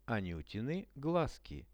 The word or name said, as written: anutiny glazki